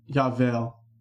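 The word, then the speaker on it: Yavel (יובל): I just looked it up because I don't remember the word Yavel. Yavel is how it's pronounced.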